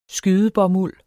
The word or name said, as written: skydebomuld